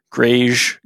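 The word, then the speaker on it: greige: So anyone listening, it's G-R-E-I-G-E. So grayish goods is how it would kind of read. – Yeah. I've heard it referred to as grayish goods before. Mm-hmm. I say gray goods.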